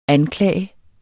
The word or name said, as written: anklage